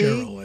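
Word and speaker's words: Sherrill: So my favorite part of this is, and you'll help me if I mispronounce the name, Mickey Sherry, a congressperson from New Jersey. – Cheryl, I think it is.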